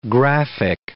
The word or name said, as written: graphic